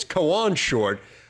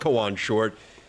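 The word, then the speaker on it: Kawann Short: It's Kawan Short. But it turns out it's not Kawan Short. It is Kawan Short.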